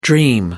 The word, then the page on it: dream: American English